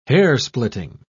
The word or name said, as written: hairsplitting